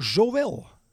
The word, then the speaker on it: Zowel: Ehm, van architectenbureau Zowel. Zeg ik het zo goed met deze klemtoon? – Dat klopt.